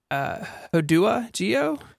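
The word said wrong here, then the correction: HoudahGeo: Huda Geo.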